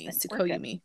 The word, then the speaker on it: Tsukuyomi: Tsukuyomi.